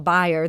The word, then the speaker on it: Bayer: And as they're referring to in that clip from the film, when they talk about Bayer, that's actually the correct German pronunciation of Bayer, which now owns our local company, Monsanto.